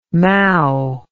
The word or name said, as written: mowe